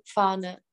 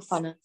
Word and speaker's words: Fahne: Mhm, a je to jakoby s F, ale čte se to fáne. Tady to se čte krátce jako fane.